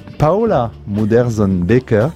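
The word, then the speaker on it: Paula Modersohn-Becker: Paola Muderzon-Baker, puisque c'est comme ça qu'il faut prononcer le nom de cet artiste, le sous-titre de l'exposition L'intensité d'un regard, c'est au Musée d'art moderne de la ville de Paris.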